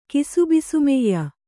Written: ಕಿಸುಬಿಸುಮೆಯ್ಯ

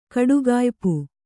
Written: ಕಡುಗಾಯ್ಪು

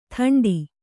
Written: ಥಂಡಿ

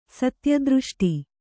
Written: ಸತ್ಯದೃಷ್ಟಿ

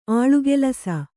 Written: ಆಳುಗೆಲಸ

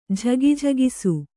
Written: ಝಗಿಝಗಿಸು

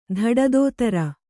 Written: ಧಡದೋತರ

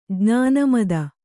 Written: ಜ್ಞಾನಮದ

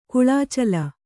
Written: ಕುಳಾಚಲ

